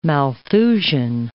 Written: Malthusian

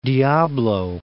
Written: Diablo, Mount

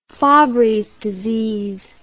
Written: fabrys disease

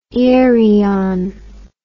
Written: irian

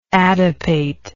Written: adipate

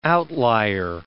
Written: outlier

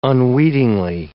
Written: unweetingly

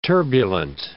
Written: turbulent

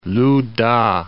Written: Lu-ta

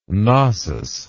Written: Knossos

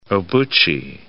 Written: Obuchi

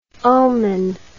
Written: ulmin

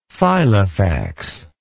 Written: Filofax

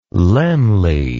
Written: Laemmle

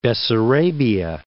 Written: Bessarabia

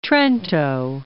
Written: Trento

